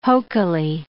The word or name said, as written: hokily